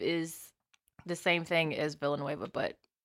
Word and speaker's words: Villeneuve: I think it's just Villeneuve.